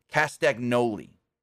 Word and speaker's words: Castagnoli: If you want to say it how it's spelt, it's Claudio Castagnoli, but it's actually, if you say it phonetically, it's Castagnoli. Or Castagnoli, however you want to say it. If you say it phonetically, Castagnoli.